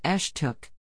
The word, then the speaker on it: Estoc: That's the name the Spanish gave the nation whose members referred to themselves as Estoc, pronounced Eshtook.